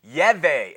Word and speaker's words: Yahweh: It's long been questioned, what does the YHWH, not what does, how do we pronounce that? Now, Americans say Jehovah. Yehu is another one. Yahweh is another one. Yivi, Jehu, Yahu, Yahu. Jahio and Jove are all just as good names as pronunciations as Jehovah for the YHWH.